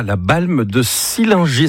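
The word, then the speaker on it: La Balme-de-Sillingy: Et bon, ce début de semaine, on accueille Laurent avec ici, Pays de Savoie, la Balme de Sillinger. Est-ce que c'est comme ça qu'on prononce l'endroit où tu vis, Laurent ? – C'est parfait.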